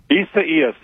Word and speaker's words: Isaias: But the one, Isaias, is that how it's pronounced? – Isaias, that one's back.